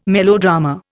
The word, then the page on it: melodrama: Indian